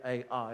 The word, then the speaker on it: Ai: we're going to get to this little town called Ai, that's how you pronounce it, Ai, that's how you spell it, anyway, and they charged it, and they were defeated, because God wasn't with them.